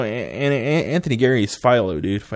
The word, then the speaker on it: Anthony Geary: Anthony Geary.